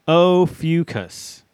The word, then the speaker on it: Ophiuchus: Ophiuchus is how it's pronounced.